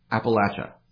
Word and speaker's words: Appalachia: Okay, so there were the camps early in summer, and then from there it moved to Appalachia.